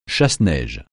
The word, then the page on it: chasse-neige: français